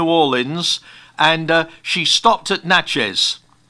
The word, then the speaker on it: Natchez: Now, you were, grew up in, how do you pronounce it, Natchez in Mississippi? – Yeah, Natchez, Mississippi.